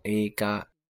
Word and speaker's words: 映画: 那映画读作 a 嘎 a 嘎，那由此延伸，电影院就被称为是映画馆，读作 a 嘎康 a 嘎康。